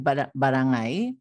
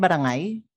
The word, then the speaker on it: barangay: So William Henry Scott had observed also in his book in Barangay, is that right? Barangay? Barangay, right? – Barangay is the archaic pronunciation. Yeah. You can say barangay. Barangay. It's correct. Barangay.